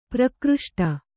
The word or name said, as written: ಪ್ರಕೃಷ್ಟ